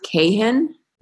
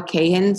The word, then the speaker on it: Kahan: and I believe it's pronounced Kahan, Noah Kahan. So it's K-A-H-A-N.